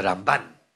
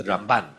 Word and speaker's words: Ramban: So he would say that the way you distinguish it is that which syllable you stress, it's the Rambam and the Ramban. So this is the Ramban.